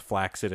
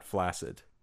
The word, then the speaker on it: flaccid: For anybody in the audience who knows, the word flaccid should be pronounced flaccid and not flaccid.